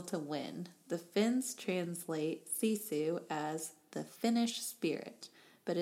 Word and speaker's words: sisu: So now we move into part of the book where it talks about the word grit versus this Finnish word pronounced sisu, spelled S-I-S-U.